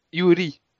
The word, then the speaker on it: Yuri: Parce que, alors, en fait, le Yuri, il faut les prononcer, les R, il faut les prononcer L.